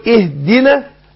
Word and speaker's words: اهدنا: برضو اهدنا، معلش انا دقيقة بس، اقول اهدنا ابين برضو الكسر بتاع الايه اهدنا، اقول اي مش اه مش ايه، يبقى انطق الهمزة المكسورة المبدوء بها زي حرف الاي بالانجليزي، ما